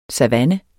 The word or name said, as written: savanne